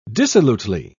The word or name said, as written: dissolutely